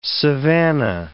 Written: savanna

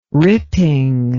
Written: ripping

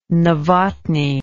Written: Novotny

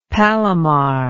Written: palomar

